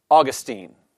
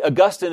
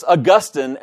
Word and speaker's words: Augustine: When the final syllable is dropped from the Latin name in forming the anglicized name, Augustine retains the accent on the penult rather than wrenchingly shifting it to the antepenult, as in the case of Augustine. He's serious about this. In this way, Augustine is closer to the original pronunciation pattern, and it thus constitutes a more natural and appropriate pronunciation.